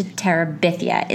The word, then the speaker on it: Terabithia: Bridge to Terabithia is the proper pronunciation. – I think if you grew up Catholic and you constantly heard the name of the place Arimathea, it would make sense to say it Terabithia, you know? – Okay.